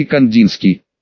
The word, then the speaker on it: Кандинский: Затем, Стравинский и Кандинский, простите, это произносится как Стравинский и Кандинский.